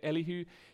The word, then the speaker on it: Elihu: And so Elihu, is that how you say it? Yeah, Elihu, he says something similar to the three friends.